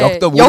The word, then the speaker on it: 역덕: 역적 아니고요. 네. 엽덕 아니고요. 역덕인데.